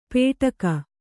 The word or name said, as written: ಪೇಟಕ